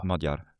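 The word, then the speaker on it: Magyar: Also was will jetzt, also wie wird er ausgesprochen? Modjar. Okay, was will der jetzt ändern?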